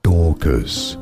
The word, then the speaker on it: Dorcas: Dorcas. – Mm-hmm. – Dorcas. – Mm-hmm. – Stress on the first syllable.